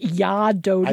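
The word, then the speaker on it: Jag är döden: Jag Ar Doden is pronounced Ya Doden.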